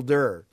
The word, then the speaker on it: Dirr: Yeah, and his last name is spelled... – D-I-R-R. – Yeah, I always misspell it. It's Michael... Michael D-I-R-R. – Yes. – Michael Durr.